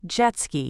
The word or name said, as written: jet ski